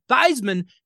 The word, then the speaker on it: Theismann: This guy used to be known as Joe Theismann when he was in high school, but in college, he changed the pronunciation to Theismann because it rhymed with Heisman.